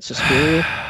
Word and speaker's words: Suspiria: Suspiria? – Yes.